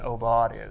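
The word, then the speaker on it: aubade: So here's Obaud is how you pronounce it.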